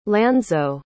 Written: lanzo

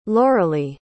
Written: lauralee

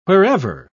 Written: wherever

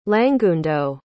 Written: langundo

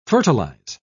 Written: fertilize

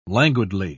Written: languidly

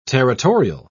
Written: territorial

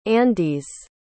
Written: andes

